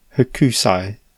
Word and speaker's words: Hokusai: Hokusai.